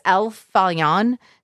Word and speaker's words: Farallon: but it's El Farallon. So it's F-A-R-A-L-L-O-N. So you don't pronounce the L's, and that's what gets me every time.